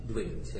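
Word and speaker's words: Dwiggins: I mean, I really like William Addison Dvigins, who I mentioned before, American type designer and book designer, first half of the 20th century. – Dvigins? Yes, pronounced? – Dvigins, yeah.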